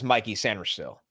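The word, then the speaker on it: Mikey Sainristil: And he introduces himself as Mikey Sanristil.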